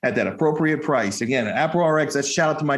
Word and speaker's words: AproRx: And say it right. It's not a pro. It's not a Prox. It's AproRx. Everybody knows what that means. That's short for appropriate prescription.